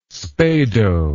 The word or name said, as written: spado